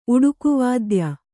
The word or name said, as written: ಉಡುಕುವಾದ್ಯ